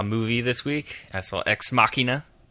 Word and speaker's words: Ex Machina: Yeah, and then some people say like Ex Machina.